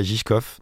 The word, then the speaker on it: Žižkov: J'ai fait une vidéo dessus, j'ai pas envie de trop vous teaser, mais l'idée c'est que moi j'habitais à Žižkov, donc ça s'écrit Z-I-Z-K-O-V,